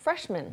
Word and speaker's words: freshman: man이라는 단어가 에 발음을 하죠, 원래. – Uh-huh. – 그런데 이게 한 단어가 되면서 그렇게 freshman이라고 하진 않아요. – No, freshman. – man처럼 발음합니다. freshman. – freshman. – 이게 신사를 가리키는 gentleman이라고 하지 않는 것처럼 gentleman. – gentleman. 네. yeah. gentleman. freshman. – 한 단어로 들어가면서 뒤에 나오는 man이 약간 약해지면서 man이 됐어요.